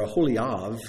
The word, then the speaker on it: Aholiab: Aholiab, or Aholiab is a better way to pronounce it.